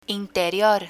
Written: interior

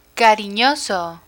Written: carinoso

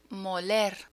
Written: moler

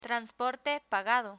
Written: transporte pagado

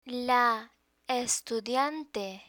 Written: la estudiante

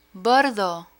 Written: bordo

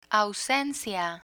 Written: ausencia

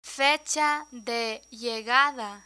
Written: fecha de llegada

